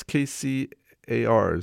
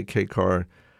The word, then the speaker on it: K-Car: I believe that's how you say it. It's K-C-A-R, so could be that too. But I'm going to say K-Car.